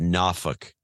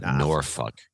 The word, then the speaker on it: Norfolk: And then my Norfolk Southern is pronounced Norfolk, not Norfolk. – Norfolk. – I get a bunch of notes from people in the area. And it's not pronounced that way. It's not Nor. It's Norfolk. Norfolk. And so Norfolk Southern is down.